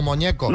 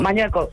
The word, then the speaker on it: Mañueco: Es el actual presidente de Castilla y León, Mañueco o Moñeco? Mañueco, Mañueco.